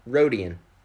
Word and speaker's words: Rodian: It's Rodian, right? – Rodian. – Rodian, perfect. – Yeah, like Greedo, you know, the Rodians.